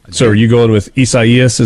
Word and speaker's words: Isaias: Right now, the timeline, somebody said that it's, Caleb in Starkville says that this is pronounced Isaias, the Spanish and Portuguese pronunciation for Isaiah.